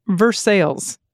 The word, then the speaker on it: Versailles: And Versailles, pronounced Versailles.